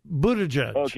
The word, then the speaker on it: Buttigieg: I don't know if you heard Pete Buttigieg. Yeah, I'm probably saying his name wrong, but who cares? – No, you got it right. Buttigieg.